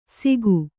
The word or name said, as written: ಸೀಕು